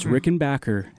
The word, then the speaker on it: Rickenbacker: He said it right. Oh. It's Rickenbacker.